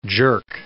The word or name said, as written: jerk